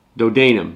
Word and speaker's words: Dodanim: The sons of Javan were Elisha, Tarshish, Kittim, and Dodanim. Dodanim. There we go. I did say it right the first time.